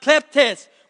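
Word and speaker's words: kleptes (κλέπτης): Thief is a word in the Greek pronounced kleptes, which we get the word kleptos, or those that steal things or like to cling to things.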